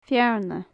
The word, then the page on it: fearna: gaelic